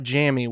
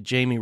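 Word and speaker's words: Jammie: that's J-A-M-M-I-E. That's not Jamie, which is what a lot of people like to pronounce. It's actually Jamie Robinson.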